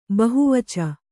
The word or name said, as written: ಬಹುವಚ